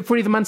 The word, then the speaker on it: Friedman: Y la cuestión es que Friedman se pronuncia muy similarmente a Freeman o hombre libre.